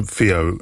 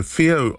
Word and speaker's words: FiiO: Okay, two products from Fio. So Fio are an audiophile brand. They're known by people who love their audio. They're a well-known brand. It's spelled F-I-I-O, strangely.